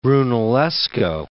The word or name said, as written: Brunellesco